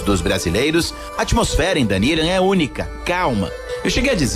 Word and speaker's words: Dunedin: Para facilitar a sua busca, se escreve Dunedin, Dunedin, mas os americanos falam Dunedin.